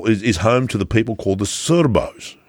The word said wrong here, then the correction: Sorbs: S-O-R-B-S, Sorbs. S-O-R-B-S, right? Sorbs, right?